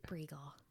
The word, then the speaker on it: Bruegl: Bruegl.